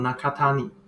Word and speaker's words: 中谷: 首先呢，是要介绍一间叫做一城寺中谷的甜点店，中间的中山谷的谷，日文呢念作 Nakatani。